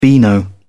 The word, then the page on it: beano: British English